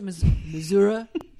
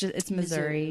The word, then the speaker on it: Missouri: Is it Missouri? You have to say. That's how the real Southern people say it, but it's Missouri.